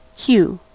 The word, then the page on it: hue: American English